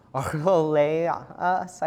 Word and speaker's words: Archelaus: The right way to say it is Archelaus, I think is right. You got to get that phlegm.